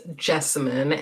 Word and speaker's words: Jessamyn: You can find me on social media at my name is Jessamyn, and that is spelled J-E-S-S-A-M like monkey, Y-N like Nancy.